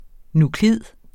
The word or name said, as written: NUKLID